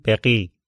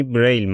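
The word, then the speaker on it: Braille: فامیلیشون هم تو فرانسوی اگه درست بگم میگن بقی، ولی خب به تلفظ انگلیسیش یعنی بریل مشهوره، ما هم همون میگیم بریل.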